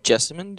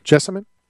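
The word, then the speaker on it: Jessamyn: Well, how do you say her first name? Jessica? Jessica? It's not Jessica. Duke? How do you say Duke's first name? – Jessamine Duke. – Jessamine? Mm-hmm.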